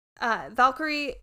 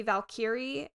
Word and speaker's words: Valkyrie: And Valkyrie can be pronounced like Valkyrie, or it can be Valkyrie, and I am going to go with Valkyrie just because that's how I've always said it, okay.